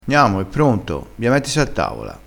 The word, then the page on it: mettisi: dialettale